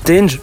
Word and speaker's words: Tendry: Et sur le territoire français, effectivement, il y a Tendry, mais ça se prononce Tenge parce qu'il est d'origine malgache et c'est comme ça qu'on dit.